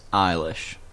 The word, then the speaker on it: Eilish: Eilish, pronounced Eilish.